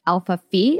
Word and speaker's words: Alpha Phi: Alpha Phi.